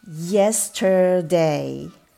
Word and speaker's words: yesterday: で R の音も落とさずに発音しましょう。 t e r tur ですね。 yesterday yesterday t e r の、え t e r の部分を丁寧に発音してみましょう。